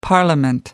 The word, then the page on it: parliament: American English